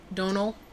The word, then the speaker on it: Domhnall: But I definitely feel like Domhnall is, is that how you pronounce his name? Domhnall? – Donal.